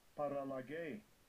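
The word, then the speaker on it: Paralage (παραλλαγή): Paralage.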